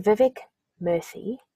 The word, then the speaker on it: Vivek Murthy: vivek murthy